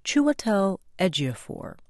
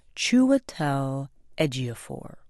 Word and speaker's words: Chiwetel Ejiofor: Chiwetel Ejiofor. One more time. Chiwetel Ejiofor.